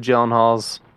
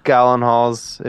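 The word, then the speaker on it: Gyllenhaal: I mean, the Gyllenhaals. The Gallenhalls, if you're my girlfriend, that's how she pronounces it. – So she's wrong.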